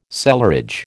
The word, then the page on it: cellarage: American English